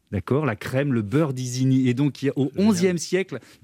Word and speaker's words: Isigny: Et d'Isigny, ça se prononce Disney en américain, et donc le Walt Disney.